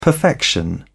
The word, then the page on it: perfection: British English